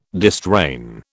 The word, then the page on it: distrain: British English